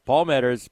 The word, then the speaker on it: Meadors: And so I mentioned that, my name's been pronounced many different ways and probably been called lots of things behind my back as well. But, Palmeters, PAULMEADORS.